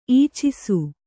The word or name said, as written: ಇಚ್ಚೈಸು